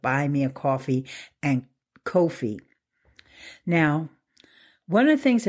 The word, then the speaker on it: Ko-fi: But this one is called Ko-fi. It's spelled K-O-F-I.